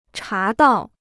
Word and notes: 茶道: chá dào

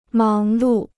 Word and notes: 忙碌: máng lù

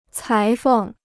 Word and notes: 裁缝: cái feng

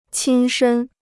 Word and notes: 亲身: qīn shēn

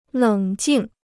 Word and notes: 冷静: lěng jìng

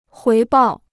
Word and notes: 回报: huí bào

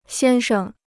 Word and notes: 先生: xiān sheng

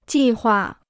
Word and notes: 计划: jì huà

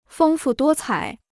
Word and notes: 丰富多彩: fēng fù duō cǎi

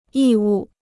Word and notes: 义务: yì wù

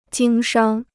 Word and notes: 经商: jīng shāng

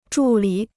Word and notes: 助理: zhù lǐ

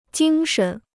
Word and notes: 精神: jīng shen

